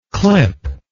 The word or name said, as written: clippable